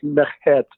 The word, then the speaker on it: Merkhet: Merkhet, um, I think that's how you pronounce it. – Yeah, the kh uh, is something like coughing, so it's like Merkhet or merkhet, uh-huh.